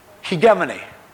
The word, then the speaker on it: hegemony: It's funny to pronounce too. It's a long E followed by a short E. Hegemony.